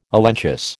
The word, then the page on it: elenchus: American English